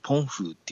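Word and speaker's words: 澎湖: はい、ここは防湖島といってですね、まあ、はい、現地の発音で言うとポンフーっていうんですけども、この台湾海峡に浮かんでる島なんですよね。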